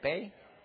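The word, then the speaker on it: bae: Am I saying that right? Bay or ba? Bay? Bay.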